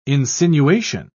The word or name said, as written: insinuation